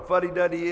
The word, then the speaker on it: fuddy-duddy: Anybody know what a fuddy duddy is? You know what a fuddy duddy is? – Yep. – Fuddy duddy. – Funny duddy. – No, not funny. Fuddy.